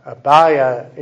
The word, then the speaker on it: Abia: Spelling and pronunciation are slightly different, but Abia is giving the more Greek rendering of Abijah, or sometimes J in Hebrew is like a Y sound, Abia.